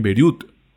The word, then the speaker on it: Beirut: Eh, lo hablábamos ahorita de la explosión que pasó en, en Beirut.